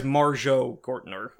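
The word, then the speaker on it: Marjoe Gortner: So it stars, and I'm going to mispronounce this, Marjorie Gortner, Carolyn Monroe. – Carolyn Monroe. – Carolyn. – That's a stage name right there. – Well, it is. I'm sorry. The first guy is Marjo Gortner.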